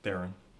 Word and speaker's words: Theron: Joining us down this Friday discussing the film he saw this week, Atomic Blonde, the Charlize Theron Theron, like heron. – Theron, heroin? – Like heron, like the bird. – Oh, heron. Theron? – Yeah. Theron.